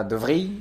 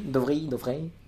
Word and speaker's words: De Vrij: Je sollicite pour la correction de la prononciation, s'il te plaît, du défenseur. – Ah, De Vrij. – De Vrij. – De Vrij, pardon.